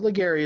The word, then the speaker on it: Ligairi: But Ligairi is correct. There's an air right in the middle, A-I-R.